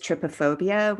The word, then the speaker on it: trypophobia: I believe it's trypophobia, which is the way that you pronounce it.